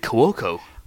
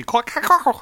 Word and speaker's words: Cuoco: Uh, a very chatty young lady who I believe had asked uh, Kaley Kuko about kissing her co-stars. – There you go. Is that how you pronounce it, Kaley Kuko? I I thought it was Kaley Cuoco. – It's actually Cuoco.